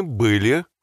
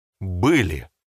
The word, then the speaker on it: были: That's a very Russian sound, that word были. – Были. – To make your pronunciation more authentic, try puffing your cheeks as you say it. Like try saying brr. See how you kind of puff your cheeks? Brr, it's cold in here. Brr, были.